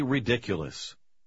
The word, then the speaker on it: ridiculous: В последнем слове вы ставите ударение на второй слог. – Don't be ridiculous. Ridiculous. – 7. – The mixture was too viscous. Mixture. – Как и сочетание T-U-R-E, S-U-R-E также произносится не так, как читается.